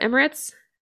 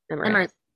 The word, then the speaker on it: Emirates: So on May 3rd, 2010, a janitor noticed some suspicious behavior about a man in the Emirates. Is that how you pronounce that word? Emirates? Emiratis? Emirates. – Emirates. – It is? Okay, good. I was questioning myself for no reason.